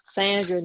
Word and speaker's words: Sandra: Sandra Newsom, N-E-W-S-O-M-E. – And that was Sandra, S-A-N-D-R-A? – Yes.